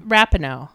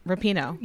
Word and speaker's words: Rapinoe: Rapinoe. – Rapinoe.